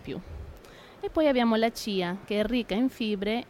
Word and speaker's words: chia: Quindi quelli che noi in Italia chiamiamo semi di chia? Esattamente. Così? Okay. Sì. In realtà si pronuncia Chia, allora Chia.